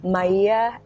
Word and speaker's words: Marija: Maia Abney.